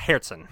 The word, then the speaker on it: Herzen: Because his name is German, so it's Herzen.